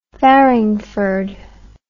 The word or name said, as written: farringford